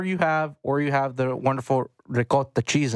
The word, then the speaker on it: ricotta: I don't eat it, but I definitely call it ricotta. It's like seven A's in it when I say it. Ricotta.